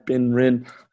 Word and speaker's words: Ben Rin: Which rhymes with my name, Ben Rin.